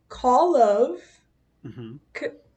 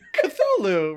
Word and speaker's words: Cthulhu: Okay, it's Call of Cthulhu. – Yeah, that's it. Cthulhu, right.